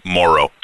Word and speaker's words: Morrow: Morrow.